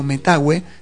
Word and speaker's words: Metahue: Ahí aparece también en Portal Disc, aparece mi música, también pueden ver como Metagüe, se escribe M-E-T-A-H-U-E.